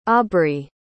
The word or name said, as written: aubree